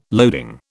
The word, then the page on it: loading: British English